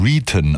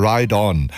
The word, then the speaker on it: Riton: Er schreibt sich nämlich R-I-T-O-N und ich neige manchmal zu so einer leicht deutschen Aussprache und habe ihn womöglich jahrelang Reaton ausgesprochen. Er spricht sich aber Ride On und hat,